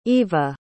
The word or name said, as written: ivah